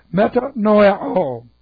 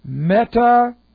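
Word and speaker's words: metanoeo: All right, M-E-T-A-N-O-E, or N-O-E, long O. Meta-noia-o. N-O-E, long O. Meta-noia-o.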